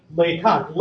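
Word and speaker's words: Lacock: You refer to Henry Fox Talbot's home as Laycock. This is not a French-derived name, but an old English one from Laycock, related to the English word lake. So this should be Laycock. Laycock, and like all English as opposed to American, proper nouns, the emphasis is not on the second syllable, so Fox Talbot lived at Laycock.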